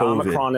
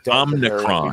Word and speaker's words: Omicron: Omicron is an acceptable pronunciation. Biden said was Omicron. – We've got holiday gatherings. – Which is not the correct pronunciation. – I don't think Omicron is correct. – It's maybe more of an English interpretation of the Greek pronunciation. Omicron is acceptable as far as I would say.